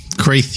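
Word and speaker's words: Chreathe: They've got the Chronicles of Kreath, I want to say, It's spelled C-H-R-E-A-T-H-E. I want to say Creath. – Yeah, Creath, yeah.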